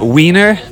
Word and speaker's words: Wien: It's funny, there's a lot of signs that said Wiener, but it's pronounced Wiener. Wiener. That's like something Wien, W-I-E-N, or Wien, means Vienna.